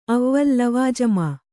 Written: ಅವ್ವಲ್ ಲವಾಜಮಾ

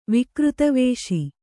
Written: ವಿಕೃತವೇಷಿ

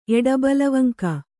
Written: ಎಡಬಲವಂಕ

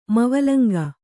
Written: ಮವಲಂಗ

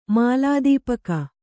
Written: ಮಾಲಾದೀಪಕ

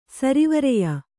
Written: ಸರಿವರೆಯ

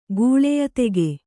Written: ಗೂಳೆಯದೆಗೆ

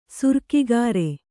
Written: ಸುರಿಗೆಕಾರ

